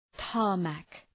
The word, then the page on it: tarmac: ‘tɑ:rmæk